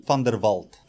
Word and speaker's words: van der Walt: How do I go with that pronunciation? – Yeah. It's not bad. It's not bad. – You say it for me. Just van der Walt.